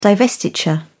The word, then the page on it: divestiture: British English